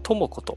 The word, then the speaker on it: 智子: 智子と読みます。